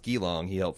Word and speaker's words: Geelong: The show wasn't only filmed in Melbourne, but it was also filmed in Geelong, which is pronounced Geelong. It's spelled Geelong and pronounced Geelong.